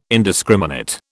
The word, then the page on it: indiscriminate: British English